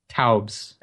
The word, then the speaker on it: Taubes: Did you say his name is Gary Tubbs? – Taubes, A-U-B-E-S.